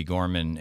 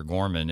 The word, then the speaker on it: Gorman: Gorman, M.D., or Dr. Gorman, and it'll pull up on the website. – You might want to spell that, G-O-R-M-A-N.